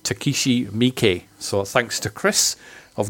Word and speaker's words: Miike: I'd have probably called him Takeshi Mike if I'd not have heard Chris pronouncing his name on the podcast a few times,